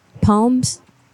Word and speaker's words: Psalms: Psalms, P-S-A-L-M. – Palms? – Yeah, it's spelled like palms but with an S in there.